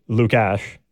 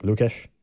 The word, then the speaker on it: Lukáš: I actually paid more attention to how they say his name on the broadcast, and it was Guy Hebert, who I'm assuming has talked to him quite a bit. It's Lukáš, like Lukáš,